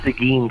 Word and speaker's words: Seguin: That's actually Seguin, Texas, where KSMG is. That's where I was at. It's spelled S-E-G-U-I-N, but yeah, it is Seguin, Texas.